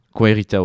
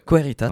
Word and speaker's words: Querita: Euh, tu as sorti, en plus de cet album, tu as sorti plusieurs singles et un premier album qui s'appelle Querita. je ne sais pas si je le prononce bien. – Querita, ouais. Querita, très bien.